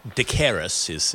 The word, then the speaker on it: Decaires: Now, if you're confused by the name, D-E-C-A-I-R-E-S, but it's a Portuguese name, so I'll give it my best attempt at pronunciation there, I'm sure. Jacaires is how they would say it in an anglicised way.